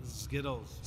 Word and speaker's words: Zkittlez: Skittles. – Skittles with a Z. Zittles. Okay, I didn't know Zittles. If I didn't pronounce the Z or the K. So Z-K-I-T-T-L-E-Z. Skittles. Skittles.